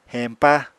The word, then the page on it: heñpa: Apatani